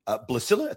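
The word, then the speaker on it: Blaesilla: That is focusing on the death of her daughter, Blasilla. I think that's the correct way to pronounce it. Is that the correct way? – Correct. – Okay.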